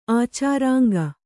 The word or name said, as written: ಆಚಾರಾಂಗ